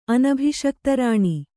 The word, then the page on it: ಅನಭಿಷಕ್ತರಾಣಿ: anabhiṣiktarāṇi